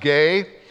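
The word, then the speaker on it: gay (γῆ): The Greek word for earth is the word gay. You see it there on the screen. It's pronounced gay.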